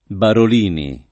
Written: Barolini

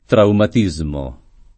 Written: traumatismo

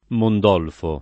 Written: Mondolfo

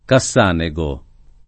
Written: Cassanego

